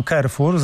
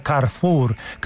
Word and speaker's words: Carrefour: Mówiłem Carrefour z angielska, a to przecież jest francuski sklep, właściwie Carrefour. Carrefour powinno być powiedziane, żeby tak było ładnie po francusku. I tak należy wymawiać. Nie Carrefour, tylko Carrefour. Jeszcze z akcentem na ostatnią sylabę, więc policzmy.